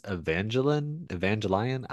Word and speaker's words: Evangelion: Is it Gellion or Angelin? – Neon Genesis Evangelion. – Oh, it's not like evangelical? Like, that's not how you say it? – No, it's Evangelion.